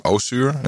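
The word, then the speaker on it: Össur: And I do not know how to pronounce this name. Osser, O-S-S-U-R, and the Osser has the umlaut above it. – Mm-hmm. – The little two dots. – Is that Osser? Osser, yeah.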